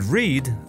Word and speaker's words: read: So the spelling is the same as of the infinitive form. Read, to read. But it's pronounced in a different way.